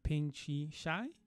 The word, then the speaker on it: Pengcheng Shi: My guest today is Dr. Pengcheng Shi.